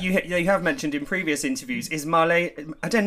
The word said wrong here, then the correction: Marlene Dietrich: I want to say Marlene, Marlene Dietrich.